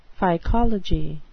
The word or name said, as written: phycology